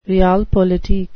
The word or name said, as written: realpolitik